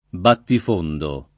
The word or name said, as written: battifondo